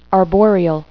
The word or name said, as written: arboreal